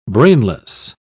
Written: brainless

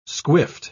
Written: squiffed

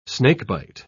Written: snakebite

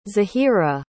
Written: zehira

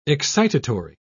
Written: excitatory

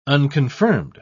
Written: unconfirmed

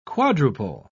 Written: quadruple

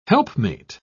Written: helpmate

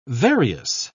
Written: various

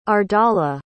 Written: ardala

